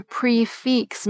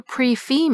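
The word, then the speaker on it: prix fixe: So a complete meal at a restaurant that's served at a fixed price is a pre-fix meal. Because pre is spelled P-R-I-X, while fix is spelled F-I-X-E. It's not a pre-fee meal, as I've heard some servers call it.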